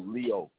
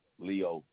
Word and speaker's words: Leo: Leo Johnson. – You said Leo? How you spell it? – No, Leo. Leo. L-E-O.